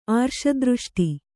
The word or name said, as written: ಆರ್ಷದೃಷ್ಟಿ